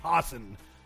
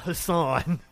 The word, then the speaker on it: Hassan: I've been saying her name wrong. This is New Hampshire, and this is a white woman. It's Maggie Hassan. Not Hassan. Maggie Hassan.